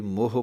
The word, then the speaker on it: मोहे: कई मोह पढ़ जांदे हन। सो सही उच्चारण है मोहे।